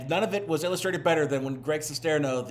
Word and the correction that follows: Sestero: is it Sestero or Sesterno? Hold on. Let me pull up. I don't want to keep saying his name wrong. Sestero. Okay, so let me re-record the opening.